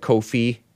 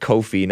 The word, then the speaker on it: Ko-fi: Let me double check Kofi. It's pronounced Kofi, not Ko-Fi. K-O-F-I.